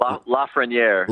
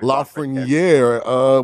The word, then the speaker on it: Lafreniere: Lafreniere. – Lafreniere.